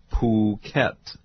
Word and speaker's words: Phuket: Phuket is how they say it in Thai.